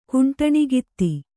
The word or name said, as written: ಕುಂಟಣಗಿತ್ತಿ